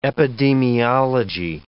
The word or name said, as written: epidemiology